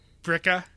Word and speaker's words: Billerica: Billerica is really Bricka. people just drop the L's, Bricka?